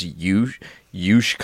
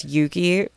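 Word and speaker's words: Youche: So we were driving one time, just a side tangent, and I was like, what is this Yush Country Club? – I was like, that's pronounced Yuki. Like, all fucking posh. – And I literally was like, no, it's not.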